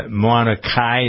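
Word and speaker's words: Mauna Kea: Some of the people, you know, The Rock has been over in Hawaii for past few days to protect Mauna Kai.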